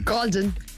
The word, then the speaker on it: Goulding: How would you say her name? – Golden. She's Golden.